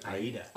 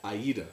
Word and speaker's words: Aida: Aida. – Aida.